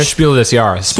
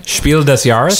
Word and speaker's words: Spiel des Jahres: Spiel des Jahres. – Thank you. Say it again. – Spiel des Jahres.